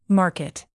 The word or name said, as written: market